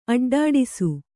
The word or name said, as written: ಅಡ್ಡಾಡಿಸು